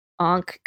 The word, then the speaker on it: ankh: Yeah, they are often carrying a ka, ankh, however you want to pronounce that. – An ankh, yeah. – Yeah, that's a symbol. Oh, let me double check. I can't spell. – A-N-K-H, an object or design resembling a cross but having a loop instead of the top arm.